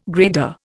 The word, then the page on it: grader: Indian